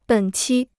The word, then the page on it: 本期: běn qī